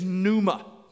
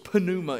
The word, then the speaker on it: pneuma: In the Greek, uh, which is the language that the New Testament was written in, the word for spirit is pneuma. It starts with a P and one of those great words with a, with a silent P at the beginning. So it's pneuma, if you will, P N E U M A.